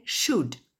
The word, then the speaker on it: should: La branche symétrique de can, c'est could, C-O-U-L-D. La branche symétrique de will, c'est would, W-O-U-L-D. Je rappelle encore, le L ne se prononce jamais. Et le symétrique de shall, c'est should, S-H-O-U-L-D. Le symétrique de may, c'est might, M-I-G-H-T.